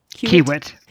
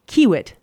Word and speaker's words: Kiewit: Did I say that right? – Kiewit. – Kiewit. Thank you.